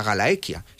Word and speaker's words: Gallaecia: forma parte de la Galaequia. Se escribe Gallaecia, se pronuncia Galaequia.